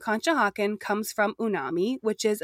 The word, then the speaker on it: Conshohocken: So first, we said it wrong. We thought it started with a P. It is, in fact, Conshohocken with a C.